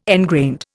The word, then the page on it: engrained: Indian